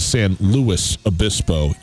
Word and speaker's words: San Luis Obispo: San Luis Obispo. You pronounce the S. I've been told.